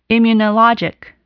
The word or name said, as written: immunologic